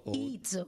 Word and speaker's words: Izzo: Est-ce qu'on dit Izzo ou Izzo? – Izzo. – Tu viens de poser la question. Si on veut bien prononcer ça, c'est Izzo. – Izzo. Comme on dirait pizza ou pizza.